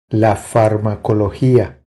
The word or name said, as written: La farmacologia